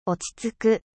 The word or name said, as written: 落ち着く